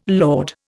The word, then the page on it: laud: Indian